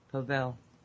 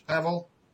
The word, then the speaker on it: Pavel: Yeah, but it's pronounced with a V. Pavel. – Pavel. – That's yeah, it's a Polish Pavel.